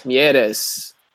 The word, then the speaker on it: Mieres: Gaston Mires led the way for Toronto. Is he even French? Why am I pronouncing him like that? – Mieres. – Yeah. – There we go.